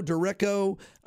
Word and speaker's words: derecho: The severe weather threat included the potential of again, is it pronounced derecho, derecho? – That's good.